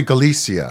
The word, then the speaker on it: Galicia: Galactic, I know I'm pronouncing it, Galicia. – Probably Galicia. – Galicia.